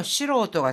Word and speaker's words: 素人: 자, 이럴 때는요, 시로오토 같은 경우에는 좀 읽기가 지금 뭐, 닝, 징과는 전혀 다르게 읽히잖아요. 잘 기억해 두시고, 시로오토라고 읽습니다.